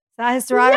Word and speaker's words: Sahasrara: Sahasrara. – Yes. That's beautifully pronounced.